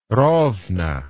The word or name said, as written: Rovno